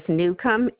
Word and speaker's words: Newcomb: The last name is Newcomb, N-E-W-C-O-M-B.